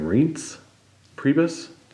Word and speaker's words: Reince Priebus: Reince? Priebus? – Reince Priebus.